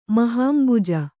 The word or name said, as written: ಮಹಾಂಬುಜ